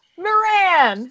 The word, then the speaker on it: Moran: Ooh, I forgot to ask you how your last name is pronounced. – Moran. – Moran. I didn't know if it was Moran or Moran. Where's the emphasis? On what syllable?